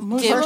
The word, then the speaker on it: Muslim: Muslim? First, it's actually not, because people say it like there's a Z, like it's M-U-Z. But it's M-U-S. It's Muslim.